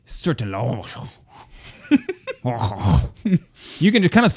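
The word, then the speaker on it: Sertillanges: It's because I think I said Sertalanges or something like that. Isn't that what I said Let's be honest. Pronunciations is not your strong suit. – That's correct. Sertalange. – Sertalange. – Yes, it's because it's French.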